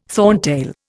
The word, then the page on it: thorntail: Indian